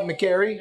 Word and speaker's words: McGarry: Did I say that right, McGarry? – Yeah, you said it right.